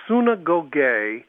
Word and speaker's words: synagoge: He says, look, the word synagoge, which is how the Greek word would be pronounced, the word actually means a gathering of people.